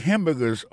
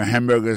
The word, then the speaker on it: hamburger: N'a pas manqué que dans mot hamburger, ça, comme t'as dit, il y a trois syllabes là-dedans, coteau appuyé, puis plus ça, c'est sous premier syllabe là.